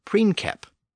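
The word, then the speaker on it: princeps: But if we go back to that original Latin word princep, we have to keep in mind that the letter C was always pronounced as a K in Latin. So in Latin, the word was pronounced as preinkep.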